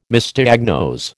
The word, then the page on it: Misdiagnose: American English